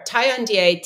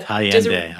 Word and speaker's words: Taillandier: Tiandie, T-A-I-L-L-A-N-D-I-E-R. But Tiandie, I got it.